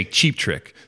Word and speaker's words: Cheap Trick: I say cheap trick, so the accent is on the first.